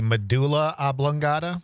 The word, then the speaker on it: medulla oblongata: By the way, John, were you talking about the medulla oblongata? – Yes. – Thank you. – That's how some people pronounce it. – Yeah, yeah, that's what right. What you said was like some sort of pasta.